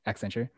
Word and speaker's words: Accenture: Accenture.